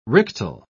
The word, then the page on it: rictal: British English